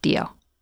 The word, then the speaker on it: Dio: And by the way, we say Dio, but it would have been pronounced Dio.